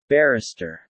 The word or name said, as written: Barrister